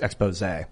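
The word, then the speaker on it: exposé: There's an accent on the last E. So it's pronounced expose.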